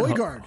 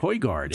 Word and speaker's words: Højgaard: Højgaard. – Højgaard.